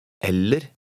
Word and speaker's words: eller: Number 13 begins with a shorter, stressed E, and the final ER is just barely pronounced. Try number 13. – Eller.